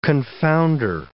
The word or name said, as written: confounder